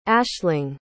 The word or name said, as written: aisling